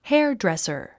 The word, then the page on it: hairdresser: hέərdrèsər; ヘアドレッサー